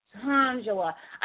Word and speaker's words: Shangela: Shangela or Shangela? – Shangela. – Shangela. I love it. I love it. But I like Shangela too. – It's Sean and Angela together, so it's Shangela.